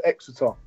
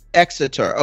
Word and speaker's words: Exeter: You have your history degree from the University of Exeter. How do you say that? – University of Exeter. – Exeter.